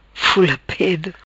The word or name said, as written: Fula Pedo